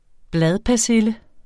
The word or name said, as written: bladpersille